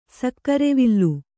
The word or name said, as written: ಸಕ್ಕರೆವಿಲ್ಲ